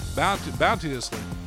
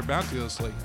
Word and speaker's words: bounteously: Bounteously. I'm going to say it right there. Bounteously. I can say it right.